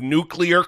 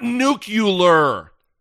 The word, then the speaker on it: nuclear: you dipshit who can't even pronounce the word nuclear correctly. It's not nuclear. It is not nuclear. N-U-C-L-E-A-R. Nuclear.